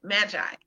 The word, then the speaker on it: MAAGI: Magi, M-A-A-G-I.